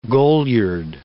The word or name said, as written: goliard